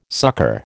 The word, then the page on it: sucker: American English